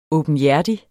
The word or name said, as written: åbenhjertig